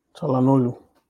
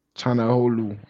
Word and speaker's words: Çalhanoğlu: Hakan Holio. I might be butchering his name. – Çalhanoğlu. – Çalhanoğlu, yes.